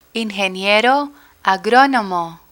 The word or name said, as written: ingeniero agronomo